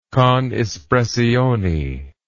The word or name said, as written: con espressione